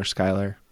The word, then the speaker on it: Schuyler: That's how we know we're pronouncing his first name properly. – Right. – Smiler, Schuyler, get it? – I think it works.